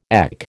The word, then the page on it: EC: American English